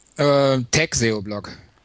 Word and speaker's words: Tag-SEO-Blog: Heißt es jetzt Tag-SEO-Blog oder Tag-SEO-Blog? Wie hättest du dich denn gerne ausgesprochen? – Tag-SEO-Blog. Also englische Variante.